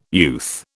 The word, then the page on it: youth: British English